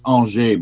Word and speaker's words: Angers: It's Angers, but it's spelled Angers.